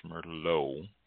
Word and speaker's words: Merlot: The T is silent, pronounced Merlot.